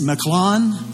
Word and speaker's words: Mahlon: And the son's name, the way they pronounce them, is Mahlon and Chilion. All right, that's how they say their name.